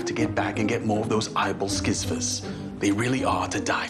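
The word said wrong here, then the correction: æbleskiver: It's pronounced Ebel Skiva.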